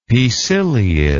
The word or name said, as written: poeciliid